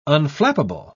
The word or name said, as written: unflappable